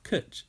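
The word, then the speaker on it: cwtch: Kutch is Welsh for cuddle. – It's good to learn new words, but do you pronounce it like that? – Kutch.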